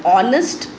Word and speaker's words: honest: I, E, Y, E, an I, an animal, an honest person. Here why I have used an before honest. H is not a vowel, but we have used it because H is silent here.